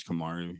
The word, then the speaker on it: Kamaru: KMRU is the name of the project. His name's Kamaru. He's an African ambient sound design artist.